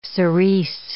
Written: cerise

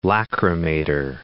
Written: lachrymator

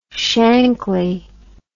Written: shankly

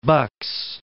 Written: Bucks

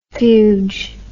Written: fuge

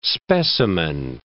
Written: specimen

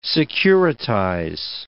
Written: securitize

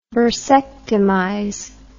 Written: bursectomize

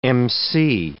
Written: emcee

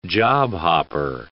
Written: job-hopper

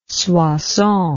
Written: Soissons

